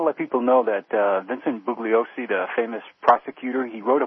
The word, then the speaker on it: Bugliosi: And by the way, he doesn't pronounce the G in his name. It's Bugliosi.